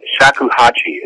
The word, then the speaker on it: shakuhachi: It's a shakuhachi is how you say it, but it's a wood flute.